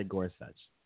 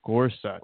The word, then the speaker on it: Gorsuch: Neil Gorsuch. Gorsuch? – Gorsuch. Let's say Gorsuch. – Gorsuch.